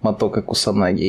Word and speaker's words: Мотоко Кусанаги: И гость сегодняшнего нашего подкаста – клуб фанатов Мотока Кусанаги. Я правильно сказал? А, да, да.